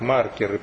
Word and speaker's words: маркеры: Я бы ударение ставил именно так, маркеры, потому что маркер — это вот карандаш, которым там рисуют. А маркер — это то, чем маркируют, это и в геодезии там используется этот термин, маркируется территория.